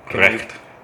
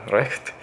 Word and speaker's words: Recht: Recht. – Yeah, Recht.